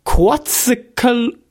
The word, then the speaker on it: Quetzalcoatl: I think you know how to pronounce this. Quetzalcoatl. – Quetzalcoatl? – Quetzalcoatl, of course, the god of light.